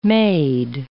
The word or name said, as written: made